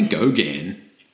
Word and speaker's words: Gauguin: Hot dogs for Gauguin? – I say Gauguin. – You actually say Gauguin. – I think I might have had an extra syllable in there.